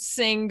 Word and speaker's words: Singha: So I will pronounce it Sing going forward to pay for my crimes.